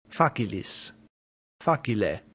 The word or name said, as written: facilis